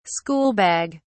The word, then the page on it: schoolbag: British English